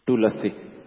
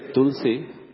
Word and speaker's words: Tulasi: It is la word is full, so it will be actually pronounced as Tulasi. But when we say Tulsi, la word is pronounced only half. – So Tulsi is not correct.